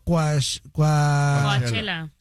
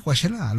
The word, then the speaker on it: Coachella: ¿Es Coachella o Coachella? – Sí, Coachella, lo dije bien.